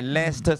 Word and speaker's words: Leicester: Eddie 不是讀 Leicester，是 Leicester Square。 – 那我說混帳起又似你不是，明明是 Leicester，那還不是 Leicester？他說是的，不過那個 C 字不發音的，所以呢是 Leicester Square，所以你講 Leicester Square 是冇人會明白的。